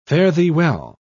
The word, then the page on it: fare-thee-well: British English